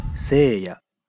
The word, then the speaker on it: 星矢: セイヤ。 – 在这个名字里，心念作セイ，是念作ヤ。